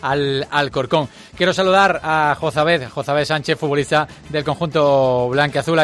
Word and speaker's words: Jozabed: No, realmente Jozabed, pero ya llevo bastante tiempo acostumbrado a que me llamen Joza, así que Joza me suena más habitual.